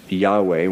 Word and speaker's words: Yahweh: And so with all that sort of false spirituality over time, it's unclear how to pronounce this YHWH. Scholars think the better, the more likely pronunciation is Yahweh.